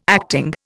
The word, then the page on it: acting: Indian